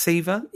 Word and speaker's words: Siva: Is it pronounced Siva? – Yeah.